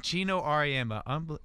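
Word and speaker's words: Geno Auriemma: Gino Auriemma.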